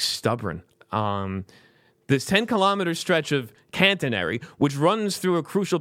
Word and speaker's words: catenary: Anyway, the 10-kilometer, 6.2-mile stretch of overhead catenary, which runs through a... Am I saying that right? Or did you said it right last time? – Catenary. Yeah, you're right.